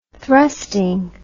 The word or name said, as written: thrusting